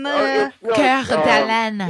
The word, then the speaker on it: Coeur d'Alene: Your goddamn town that you live in, is it Coeur d'Alene? – Coeur d'Alene.